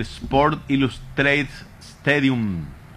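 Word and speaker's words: Sports Illustrated Stadium: En el Sport Illustrated Stadium. Así se pronuncia.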